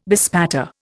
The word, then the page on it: bespatter: Indian